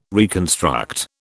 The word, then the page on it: reconstruct: British English